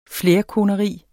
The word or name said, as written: flerkoneri